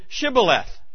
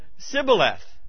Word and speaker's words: Shibboleth: They couldn't pronounce the SH, the Shibboleth, and they would pronounce it Sibboleth.